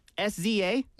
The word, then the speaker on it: SZA: SZA? – Yeah, I know.